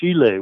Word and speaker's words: Chile: But it's pronounced Chile with a SH or a CH soft sound.